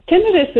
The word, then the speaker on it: tinnitus: Tinnitus, yep. – Yeah. Well, explain what that is so people understand, because the TV people say it so fast, you go, well, what does that really mean? – Tinnitus is any sort of noise that is persistent in that you hear.